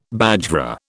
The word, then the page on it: bajra: British English